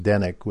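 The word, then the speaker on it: Zdeněk: And so in the British and European journals, basically Zdenek Kopal, it's called Zdenek, but it's pronounced Zdenek without the Z, Zdenek Kopal.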